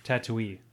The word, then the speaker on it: Tatooine: Tatooine.